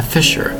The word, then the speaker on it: fissure: The actual crack in that surface is called a fissure. F-I-S-S-U-R-E. It's called fissure. F-I-S-S-U-R-E. Remember, it's two syllables. Spelled F-I-S-S-U-R-E.